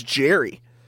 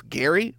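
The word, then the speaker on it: Gerry: I'm like, who is Jerry? Who is Jerry Bohannon? I'm like, oh, it's Gary? Why does he spell it like that? – That's how you, you know what, though?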